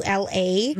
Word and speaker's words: Drkula: Congratulations to Bradley Drukla, if I'm pronouncing his last name right. Do you know Drukla's Bowling in Invergrove Heights? – I don't know that. – It's spelled like Dracula, but it's Drkula.